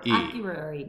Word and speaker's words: Akureyri: Is that how you say it? Akureyri. – A-K-U-R-E-Y-R-E. – Akureyri. – Yeah.